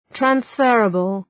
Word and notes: transferable: træns’fɜ:rəbəl

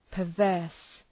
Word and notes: perverse: pər’vɜ:rs

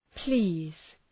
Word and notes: please: pli:z

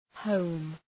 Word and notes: home: həʋm

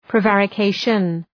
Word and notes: prevarication: prı’værə,keıʃən

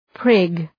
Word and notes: prig: prıg

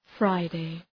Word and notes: Friday: ‘fraıdı

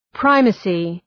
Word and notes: primacy: ‘praıməsı

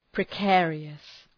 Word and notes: precarious: prı’keərıəs

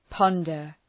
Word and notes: ponder: ‘pɒndər

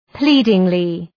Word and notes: pleadingly: ‘pli:dıŋlı

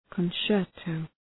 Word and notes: concerto: {kən’tʃertəʋ}

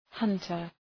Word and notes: hunter: ‘hʌntər

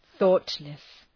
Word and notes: thoughtless: ‘ɵɔ:tlıs

